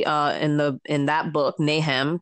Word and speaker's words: Nahum: Nahum. I hope that I'm pronouncing it right. – Nahum, maybe Nahum. – Nahum. Yes. Yeah, Nahum.